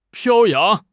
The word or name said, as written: 飘扬